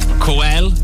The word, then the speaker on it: Coel: Coel, but it's pronounced like Noel, but with a C.